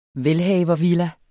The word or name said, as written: velhavervilla